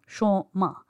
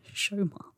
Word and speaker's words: Soma: She was like, nope, my daughter's name is S O M A. So ma, Shoma is how it's pronounced in Bengali, not Shoma.